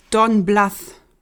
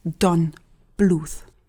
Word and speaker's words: Don Bluth: Don Bluth ist nicht Don Bluth. Er heißt Don Bluth.